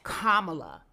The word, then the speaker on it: Kamala: So let me teach you now how to say this lady's name. – That part. – Kamala yes. – Harris. – Kamala Harris.